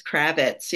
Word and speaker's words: Kravitz: It's Kravitz, yes.